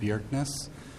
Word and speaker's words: Bjerkness: Mr. Chairman, members, for the record, my name is Dave Bjerknes. I'm a city councilor with the city of Cloquet. My last name is spelled B-J-E-R-K-N-E-S-S.